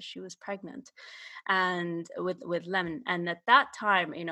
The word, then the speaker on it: Lemn: So my second book is Lemon, as he pronounces it, Lemon Cisse.